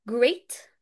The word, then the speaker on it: great: طيب هو فعلا بيحسب long E، بس عندنا استثنائين، استثنائين بينطقوا بال long E بس هما بيتكتبوا بال EA وبنحفظهم كده زي ما هما. ايه هما؟ كلمة great اللي هي عظيم great، ودي بتتكتب جي ار اي اي تي great great، جي ار اي اي تي great خلاص، بتحسب long اي.